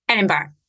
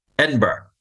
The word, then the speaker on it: Edinburgh: And we are going to go to Scotland and we're going to talk about Edinburgh. Mm-hmm. So now, pronounce it correctly. – Edinburgh. – Edinburgh. – Yeah, Edinburgh. – Because I've heard Edinburgh, Edinburgh, but Edinburgh. – Edinburgh. Yeah, that's how we'd say it.